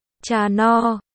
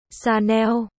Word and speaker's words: Chanel: Tương tự với hãng Chanel nổi tiếng với âm N, nếu như đọc là Chanel, thì nó mang nghĩa là kênh, cách đọc đúng là Chanel, cơ, còn có Givenchy, Givenchy, đọc loạn ba chữ này lên là được.